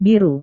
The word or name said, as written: biru